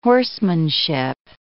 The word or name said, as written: horsemanship